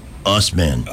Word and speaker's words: Assman: Oh, by the way, even though it's spelled A-S-S-M-A-N, he says it's pronounced Usman, which is how they pronounce ass man in Canada.